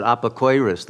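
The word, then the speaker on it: apikoros: I know among the Ashkenazi Jews, they pronounce it apokoiris.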